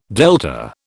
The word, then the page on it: delta: British English